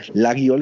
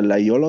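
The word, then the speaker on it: Laguiole: Ich habe auch so ein paar Laiol, das wird, ne, viele sagen Lagiol, es wird aber Laiol ausgesprochen.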